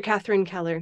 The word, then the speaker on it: Catherine Keller: is it Catherine Keller? – Yes, Dr. Catherine Keller.